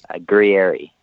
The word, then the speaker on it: Gurrieri: So it's Mike, M-I-K-E, and my surname is spelled G-U-R-R-I-E-R-I. – So how do you say that? – Grieri.